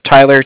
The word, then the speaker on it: Tyler: Okay, my name is Tyler, T-Y-L-E-R. – Uh-huh. – I'm calling with Cardiff. It's spelled C-A-R-D-I-F-F, as in Frank Frank.